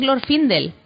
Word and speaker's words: Glorfindel: Yo digo, mira, yo sé que es así, sé que es Glorfindel, sé que lo estás pronunciando bien, pero no te sé decir por qué.